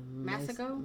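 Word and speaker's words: Masego: Masako, Masajo. – Yeah. – Massago? – Massago.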